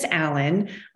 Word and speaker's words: Allyn: My name is Allyn,